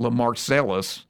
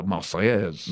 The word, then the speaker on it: Marseillaise: Here, like, it's not, this song is not Le Marcellus. The Marseillaise.